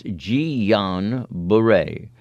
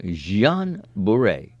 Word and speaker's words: Jean Bure: We have from Denise and from our very good friend Maggie, stop mispronouncing. It's Jean Bure. It's a French name. Jean Bure, sent from my iPad.